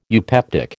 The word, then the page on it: eupeptic: American English